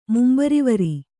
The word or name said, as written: ಮುಂಬರಿವರಿ